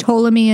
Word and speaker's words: Ptolemy: This country's capital was once named Philadelphia after Ptolemy Philadelphus, if I'm saying that right. – Ptolemy? It's Ptolemy, and is it Macedonia? – It is not Macedonia.